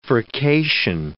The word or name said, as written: furcation